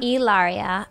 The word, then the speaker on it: Hilaria: now, how do you say you say Ilaria? Ilaria. Do people call you Hillary? Sometimes, and you know, it's the same name, right.